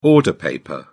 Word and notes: order paper: British English

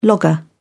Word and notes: logger: British English

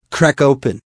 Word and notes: crack open: American English

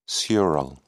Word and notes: sural: British English

